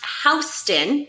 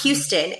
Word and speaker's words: Houston: And it is pronounced Houston. – Correct. – Not Houston.